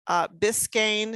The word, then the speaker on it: Biscayne: Biscayne, is that how you say it? – Yep.